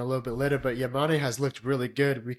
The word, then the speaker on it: Yamane: Mickey Yamane? Is that how you pronounce his name? – Mickey Yamane.